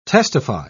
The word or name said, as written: testify